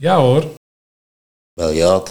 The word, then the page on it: Beljaat: Nieuw lekkerlands